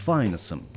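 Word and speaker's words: Phineas: В Ветхом Завете он был сыном пророка Илии и братом Офни, но друзья-джазмены отказывались от лишней гласной и звали его Файнес. Он и сам предпочитал быть Файнесом. Леонард Фезер в своей статье 61 года указывает: «Финеас ратует за то, чтобы его имя произносилось как Файнес с ударением на первый гласный».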